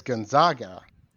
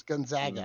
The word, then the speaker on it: Gonzaga: The Italian pronunciation is Gonzaga. But just like it's Notre Dame and not Notre Dame, okay. – Out here in the West, it's Gonzaga, and that's from the school's website.